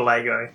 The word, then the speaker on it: Lego: Lego or Lego? – She said Lego. – Yeah, because that's the right way to say it.